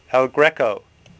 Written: Greco

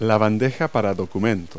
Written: desk tray

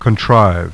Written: CONTRIVE

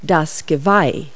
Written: antler

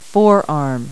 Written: forearm

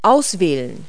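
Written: choose

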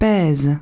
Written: pesent

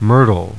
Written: myrtle

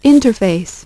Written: interface

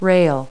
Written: rail

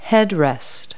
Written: headrest

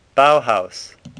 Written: Bauhaus

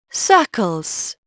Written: circles

